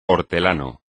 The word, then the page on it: hortelano: espagnol